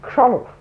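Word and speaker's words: Chrzanów: How do you spell that? – C-H-R-Z-A-N-O-W. Kronov.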